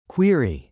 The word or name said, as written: query